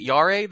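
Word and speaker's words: Jarre: Is it Yare, the score's composer? – Yes, I don't know if I've ever really said it out loud.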